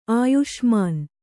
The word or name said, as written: ಆಯುಷ್ಮಾನ್